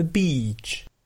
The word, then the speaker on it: beach: Alors, attention à la prononciation, il s'agit bien d'un « i » long, donc « the beach ». Parce que si tu prononces avec un i court, c'est une insulte.